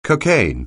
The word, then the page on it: cocaine: anglais